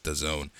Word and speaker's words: DAZN: Game that I find on a DAZN, whatever you want to call it, it's pronounced DAZN.